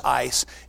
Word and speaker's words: eis: It literally uses a Greek word, E-I-S. It's pronounced ice, and it means in relationship to your desire.